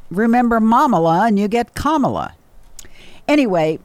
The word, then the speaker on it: Kamala: And by the way, for those of you who don't know how to pronounce her name, you know how to pronounce the word comma, like what you put in a sentence to break up phrases. C-O-M-M-A. Well, that's the pronunciation of her name. Kamala.